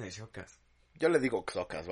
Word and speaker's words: Xocas: Es que, es que justo hablé de eso ayer con Silent, porque mi dijo, es, Chocas o Xocas, y es Xocas, porque la pronunciación de la X en español se pronuncia como KS. – Ajá. – En nuestro español. Entonces Xocas, no Chocas.